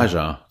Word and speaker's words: chacha: Chacha oder Chacha? Jaja, so, nicht Chacha. – Jaja, okay. – Eher so Jaja, so habe ich es wahrgenommen.